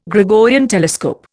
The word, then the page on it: Gregorian telescope: Indian